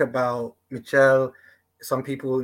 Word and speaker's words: Michel: I, I think some things you guys haven't touched on that I'd like to touch on about Michel, which I think I, I was calling him Michelle for years, but it's actually how Costa pr- pronounces it uh, ah, Michel.